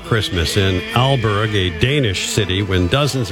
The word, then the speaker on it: Aalborg: Aalborg. – Yeah, there you go. – What? – It sounds so natural, just like that. Teacher in that classroom Adam was talking about. – Aalborg.